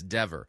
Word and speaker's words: Dever: I thought it was Deaver. It's not, it's Dever.